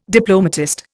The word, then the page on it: diplomatist: Indian